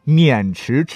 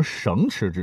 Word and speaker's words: 渑池: 好，下面这个城市的名字那就更有故事了哈，叫渑池知会的渑池，绳子的绳呢是绞丝旁啊，咱换成三点水啊，不能读成绳池知会啊。